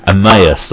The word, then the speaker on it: Emmaus: Finding Emmaus, is that right? – Emmaus.